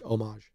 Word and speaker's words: homage: After all that, you said homage 3 times. It's just homage. You don't pronounce the I don't care what it is.